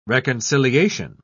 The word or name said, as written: reconciliation